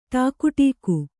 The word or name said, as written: ಠಾಕುಟೀಕು